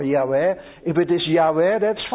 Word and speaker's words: Yahweh: And he uses the word Lord here not from Elohim, but Yahweh, that we used to read in our Bibles as Jehovah. And now all the scholars in the Jews and in the Christian world agree that that is a mispronunciation, that it should have been Yahweh.